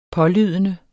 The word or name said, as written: pålydende